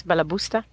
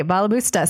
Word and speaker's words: Balabusta: It's called Balabusta. Am I saying that correctly? – Yes, Balabusta. – Okay, Balabusta. So that means perfect housewife in Yiddish.